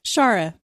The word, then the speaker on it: Shara: And Mashasha says, this is Shara. I think that's how she pronounces her name. – Shara. – There you go. I was close. Shara. – Again with the hard A's.